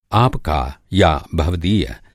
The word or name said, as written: भवदीय